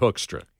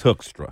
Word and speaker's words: Hoekstra: Pete Hoekstra, he pronounces it Hoekstra. – He says Hoekstra. – Hoekstra.